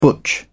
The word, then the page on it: butch: British English